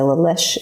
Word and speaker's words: La Leche: I don't know if anyone knows about the La Leche.